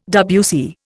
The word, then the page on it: WC: Indian